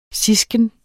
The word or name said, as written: sisken